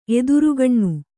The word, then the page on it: ಎದುರುಗಣ್ಣು: Kannada